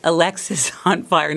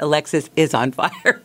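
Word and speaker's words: Alexisonfire: Which, by the way, I learned is pronounced Alexis on Fire, not Alexis is on Fire, which I got a lot of education preparing for this show.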